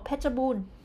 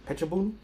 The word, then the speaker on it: Phetchabun: Oh, Phetchabun. – Phetchabun.